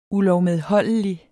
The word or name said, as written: ULOVMEDHOLDIGT